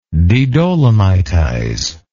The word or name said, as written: dedolomitize